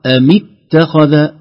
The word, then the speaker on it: اتخذ: هذه الألف إذا وصلت، إذا كان منطوق قبلها حرف أو كلمة لا تنطق أبدا، فقط إذا بدأنا نقول اتخذ، أما لو وصلنا نقول أم اتخذ، لا توجد ألف في اتخذ أبدا عند الوصل، أم اتخذ.